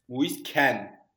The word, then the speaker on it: Moise Kean: Oğlum bu adamın adı böyle mi okunuyor lan? – Evet Moise Kean'miş abi. – Moise Kean. – Moise Kean. Ben... – Bir dakika. – Ben Moisken. – Londra'da yaşayan adam var. O telaffuzunu bize öğretsin. – Abi, Moise King.